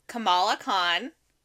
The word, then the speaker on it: Kamala Khan: It is Kamala Khan. That is how we pronounce it. That is the correct way to pronounce it.